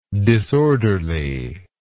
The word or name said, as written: disorderliness